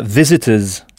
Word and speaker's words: visitors: Qui au pluriel donnera visitors avec le son z à la fin.